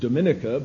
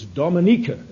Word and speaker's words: Dominica: We often pronounce it Dominica, but it's Dominica. And if you want to keep in his good graces, you never pronounce it Dominica. You pronounce it Dominica.